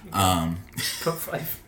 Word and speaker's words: Pfeiffer: Pfeiffer.